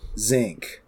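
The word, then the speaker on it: Xenk: So I we have to mention Zink. I think that's how you say his name. It's X-E-N-K.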